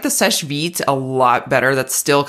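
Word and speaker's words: Sechevite: You know, she's like, you just say it Sesh Vite.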